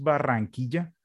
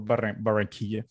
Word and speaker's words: Barranquilla: I'm living in a city called, it's pronounced Barranquilla, but you can tell Barranquilla if you want.